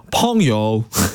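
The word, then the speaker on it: 펑요 (朋友): 하석철씨가 그 아까 주학원의 붕우 일부에서 전해드렸는데 노래 제목이 붕우가 아니고 펑요 펑요 라고 읽으셔야 됩니다.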